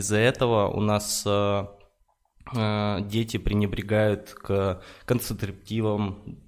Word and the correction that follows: контрацепции: правильно сказал, не знаю, неправильно. – Контрацепции. К, да, к контрацепция вот, э, делают там необдуманные шаги, идут там на тусовки, где их там насилуют и так далее.